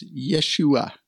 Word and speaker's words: Yeshua: And that's Yeshua, right? HaMashiach. That's how you say it, how the Hebrews would say it.